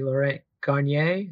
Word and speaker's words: Laurent Garnier: He was hosting a party featuring DJ Laurent Garnier. Is that how to say it? – Yeah, pretty much.